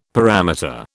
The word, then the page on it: parameter: British English